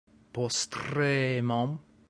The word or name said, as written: postremum